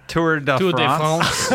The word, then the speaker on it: Tour de France: Should 100% as a French-Canadian be pronouncing that better. – Tour de France. – Yeah. – France. – De France. – There we go.